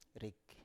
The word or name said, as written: rik